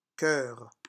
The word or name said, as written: coeur